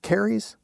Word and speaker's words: caries: How do I pronounce it? Is it caries? – Mm-hmm.